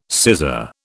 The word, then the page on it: sizar: British English